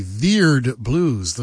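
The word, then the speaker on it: Vierd Blues: The not often played Miles Davis piece, Veered Blues. That's how it's spelled, V-I-E-R-D, Veered Blues, the name of it.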